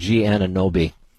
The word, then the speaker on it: Anunoby: The ball was thrown in by OG, but I never know how to say his last name, Anunoby. OG Anunoby.